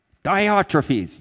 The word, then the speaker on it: Diotrephes: Diotrephes, yes. I don't know why that won't stick in my head. It's Greek, right? It's Greek to me. Diotrephes is this guy.